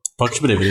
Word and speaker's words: Pocket Bravery: Pocket Bravery, é isso Pocket Bravery, você falou certo.